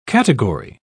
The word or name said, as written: category